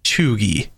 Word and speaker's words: cheugy: The word that I'm referring to is choogy. C-H-E-U-G-Y. Choogy. – Is that how it's pronounced? Choogy? – Correct.